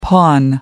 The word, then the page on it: porn: American English